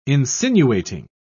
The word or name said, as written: insinuating